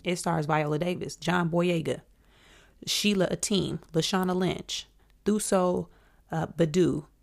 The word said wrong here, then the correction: Mbedu: Wait, no, y'all be butchering her last name. Let me get it right. Thuso Mbedu.